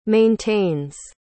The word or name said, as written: maintains